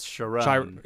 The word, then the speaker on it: Chiron: is it Chiron? I always want to pronounce it Chiron, like the Greek centaur. – Yeah, do we ever hear it? – We hear it a bunch. It's Chiron.